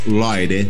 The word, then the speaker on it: Lloyd: La pronunciación correcta es Lloyd, Lloyd.